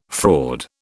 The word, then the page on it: fraud: British English